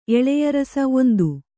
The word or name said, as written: ಎಳೆಯರಸ